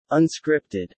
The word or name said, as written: unscripted